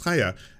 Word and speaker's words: Chaya: Uh, you convinced me that it was Chaya after someone said that uh, in like another broadcast, and I was like, I, I think that name is pronounced Chaya, and and I had been saying it correctly for so long.